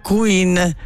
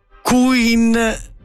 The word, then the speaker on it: Quinn: L'autrice si chiama Meghan Quinn, si pronuncia più o meno Meghan Quinn. Non Queen all'italiano.